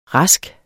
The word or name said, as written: rask